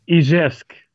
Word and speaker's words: Izjevsk: Ja, uh, Izevsk. Izevsk, ja verdorie.